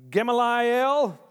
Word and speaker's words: Gamaliel: Gamaliel. "I indeed," and Acts 22:3, it says, "I indeed am a Jew born in Tarsus of Cilicia, but brought up in this city at the feet of Gamaliel, taught according to the strictness of our father's law, and was zealous toward God as you all are today."